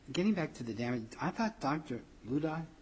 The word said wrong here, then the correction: Lauda: is that how his name is pronounced? – Lauda.